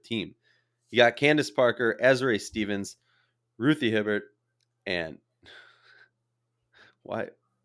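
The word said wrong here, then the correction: Hebard: I feel like I mispronounced her name. – Hebert. – Whatever. – Hebert. – Hebert.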